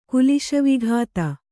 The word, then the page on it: ಕುಲಿಶವಿಘಾತ: kuliśavighāta